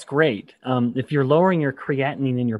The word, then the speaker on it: creatinine: Increasing fats and reducing carbs has lowered my serum creatinine. Is that how you say it? – Creatinine.